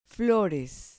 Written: flores

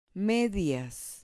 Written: medias

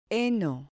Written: heno

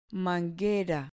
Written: manguera

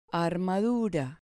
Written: armadura

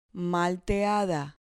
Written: malteada

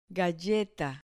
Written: galleta

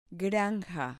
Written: granja

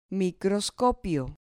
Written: microscopio

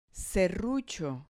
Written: serrucho

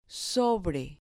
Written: sobre

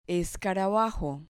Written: escarabajo

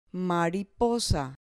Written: mariposa

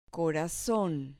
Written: corazon

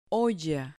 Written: olla

